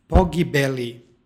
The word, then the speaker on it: pogibeli: stihu pročitali smo v pogibeli sa L. Dakle, nema jotovanja ovdje u krajnjem slogu.